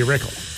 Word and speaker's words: Rykal: And it's spelled R-Y-K-A-L. – Yeah, it's a weird spelling. – So I would say Reichel.